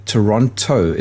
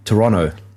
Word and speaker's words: Toronto: And he told me that the way I say Toronto is wrong. That's the T is actually more silent. It's supposed to be Toronto, like that. He said that's how it's said there.